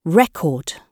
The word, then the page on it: record: British English